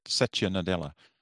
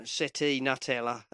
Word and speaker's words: Satya Nadella: Satya Nadella. – I can never pronounce it. You know, Seti Nutella.